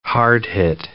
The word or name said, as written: hard-hit